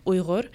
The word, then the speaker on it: Uyghur: But yeah, when I pronounce it, it's Uyghur.